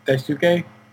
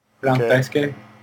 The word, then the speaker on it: Daisuke: And also, they're saying his name wrong. The guy, guardian of the Black Box. Yeah, uh-huh. I mean, Daisuke. Daisuke.